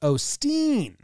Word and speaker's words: Osteen: All right, Claude Osteen. They say it's pronounced Osteen. They say that you go a little soft O. Steen.